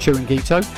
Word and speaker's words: chiringuito: And Chirin, how do you pronounce that? – Chiringuito.